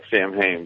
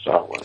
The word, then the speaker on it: Samhain: And now, Samhain is the word that, if we're looking at it through our American eyes, looks like Samhain, correct? – That's correct. It looks like Samhain, but the Celtic pronunciation is Samhain.